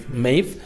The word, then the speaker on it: Maeve: Maeve, qué guapa.